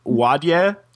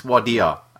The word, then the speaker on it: Wadeye: So Stuart sends us this link to this noisy, Vice noisy documentary called The Heavy Metal Gangs of Wadia. Is that how you say it? – It's spelled W-A-D-E-Y-E. So it looks like Wadi, but you know, Abbos, it's Wadia. I think it's pronounced.